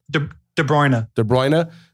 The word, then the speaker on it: De Bruyne: Is it De Bruyne? Is that how you pronounce Kevin? – De Bruyne.